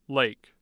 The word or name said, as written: Lake